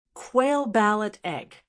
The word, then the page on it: quail balut egg: American English